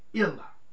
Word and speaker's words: Illah: In the African Arabic language, her name is Illa. That word is spelled I-L-L-A-H.